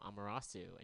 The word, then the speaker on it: Ahmerahsu: And this is the new album from Ahmerahsu, and you can find it on Bandcamp.